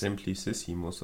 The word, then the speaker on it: Simplicissimus: Wie werden die ausgesprochen? Simplicicus? – Simplicissimus oder so. – Ja, genau.